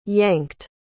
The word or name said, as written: yanked